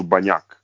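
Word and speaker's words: Bagnack: Pa evo, Slovenci kažu banjak.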